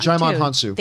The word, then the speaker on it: Djimon Hounsou: Djimon Hounsou.